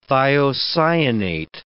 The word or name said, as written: thiocyanate